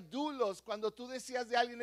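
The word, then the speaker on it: dulos (δοῦλος): Ahora, la palabra que describe esclavos, y buscamos en, en, el griego original, es la palabra dulos. Di conmigo dulos, como si fuera doble O, así más o menos se pronuncia, y quiere decir que es un esclavo.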